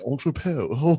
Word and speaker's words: Entrepot: I'm going to sell my NFTs on Entrepot on November. – What happened to the T? Why is it silent? What's the T is silent because we're French. We're Entrepot. Okay, lovely.